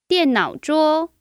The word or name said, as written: dian4nao3zhou1